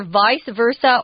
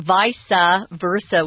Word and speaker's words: vice versa: Yeah, it's either vice versa or vice versa, which is the Latin pronunciation. I say vice versa. I guess, Tom, you say vice versa. They're both correct.